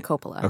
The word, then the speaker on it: Coppola: Melissa Coppola.